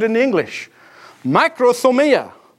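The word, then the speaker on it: makrothumia (μακροθυμία): The Greek word is this, makrothumia. It's one word, but I'm pronouncing it as if it's two.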